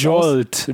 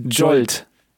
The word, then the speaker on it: Jolt: Oder nächstes Jahr soll, wie spricht man das Jolt. – Aus? – Jolt.